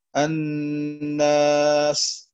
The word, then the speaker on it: الناس: والثالث هذه الفوائد هو تحسين لفظ الحروف وتجميلها، كتخليص حروف المد من الغنة نحو الناس، فهنا يكثر اللحن في هذه الكلمة، وأن ينطق حرف المد هنا الألف ومعه الغنة لأنه مجاور لحرف أغن.